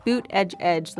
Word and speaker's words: Buttigieg: Mayor Pete Buttigieg. – Who the English have absolutely a lot of difficulty pronouncing his surname. – I know. Boot edge edge, like a boot on your foot, edge at the edge of a table.